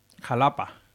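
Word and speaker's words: Xalapa: En Veracruz, en el estado de Veracruz, está Xatapa, me parece. – Jalapa. – Jalapa, se dice con X, sí. Jalapa, que se escribe con X.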